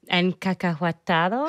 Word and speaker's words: encacahuatado: And I made the pork loin with peanut sauce, the encacahuatado. Is that right? – That's beautiful. Yes.